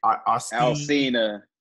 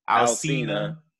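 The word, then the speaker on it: Alsina: How do you say his name? – Alcina. – Alcina. – Yeah.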